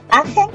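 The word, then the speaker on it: Aachen: Puedes decirlo AGE, ajá, o en español Aquisgrán.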